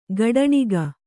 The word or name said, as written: ಕಟ್ಟಣಕ